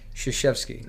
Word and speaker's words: Krzyzewski: Krzyzewski.